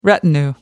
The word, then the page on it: retinue: American English